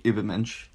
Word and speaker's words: Übermensch: Their plan was to transform humanity into the Nietzschean Übermensch. Did I say that right? – Essentially, Übermensch. – Übermensch. That was like French. – Uber. That's the you with the umlaut. Uber.